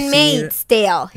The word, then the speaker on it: Handmaid's Tale: Handmaid's Tale.